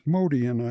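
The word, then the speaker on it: Motian: Bill Evans piano, Scott LaFaro on bass, Paul Motion on drums, Modian, I believe it's pronounced, Modian on drums.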